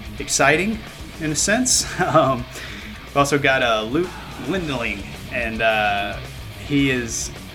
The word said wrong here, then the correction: Glendening: I believe that's how you pronounce his name, Glendening. Glendening is how you say it. I think I was trying to add an L in there.